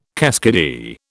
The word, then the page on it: keskidee: British English